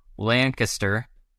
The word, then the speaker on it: Lancaster: Saying it right? Yeah. Yes. Corrected me last time. Got it down. Growing up in Lancaster on a farm, with chickens and all of the things, where did metalcore even come into the picture for you? – It's a great question. And you said it right.